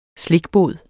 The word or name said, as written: slikbod